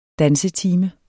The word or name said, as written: dansetime